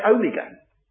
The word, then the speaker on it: omega: It's the long O, omega. There's a short O, omicron. O, micron, microscopic. O, little O. O, mega, megaphone, a loud voice, large O.